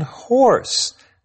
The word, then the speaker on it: hoarse: Now, that's a funny word because it sounds a lot like the word we use for the animal. You sound hoarse. But notice that it's spelled just a little differently, H-O-A-R-S-E.